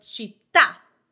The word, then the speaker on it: città: I see that mark at the end, on the last vowel, I am going to put more emphasis. For example, città, City. Felicità, happiness. Varietà, variety. Età, age. The accent is on the last vowel and it is marked with a sign in written language.